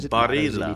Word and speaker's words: Barilla: Oh, Barilla. Italian Barilla. Barilla. It's literally Barilla.